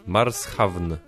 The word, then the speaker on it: Marshawn: On nie nazywa się Martian, tak jak pisze się marsjański, ale czyta się w zasadzie tak samo. – A jak się nazywa? Martian? – Marshawn. – Ale... Marshawn. – Marshawn. Marshawn Love.